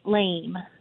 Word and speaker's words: Laime: L-A-I-M-E, lame. – His last name is lame. Is actually lame, L-A-I-M-E. It's spelled, yeah. – It's pronounced lame.